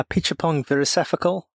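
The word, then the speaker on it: Apichatpong Weerasethakul: Is it a Pichapong Virasefakul? – That sounds very correct.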